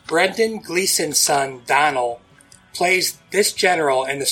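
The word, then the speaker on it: Domhnall: It's spelled weird, but it's pronounced Donald. It's spelled Dom H. Null. – Ugh. – The M and the H are silent.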